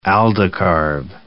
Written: aldicarb

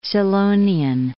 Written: Scillonian

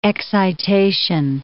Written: excitation

